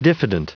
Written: diffident

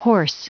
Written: hoarse

